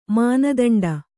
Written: ಮಾನದಂಡ

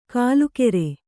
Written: ಕೂಲಿಕಾರ

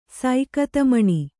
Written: ಸೈಕತಮಣಿ

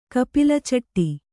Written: ಕಪಿಲಚಟ್ಟಿ

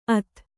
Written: ಅಥ

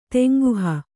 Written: ತೇಂಗುಹ

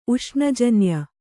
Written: ಉಷ್ಣಜನ್ಯ

